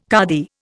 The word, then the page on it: kadi: Indian